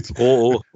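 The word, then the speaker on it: Rouault: Ähm, dann haben wir Anthony Ru Ruo, richtig ausgesprochen. Ich hoffe. Roo, Roo. Roo, Roo. Wie ähm, Renault äh, habe ich mir sagen lassen.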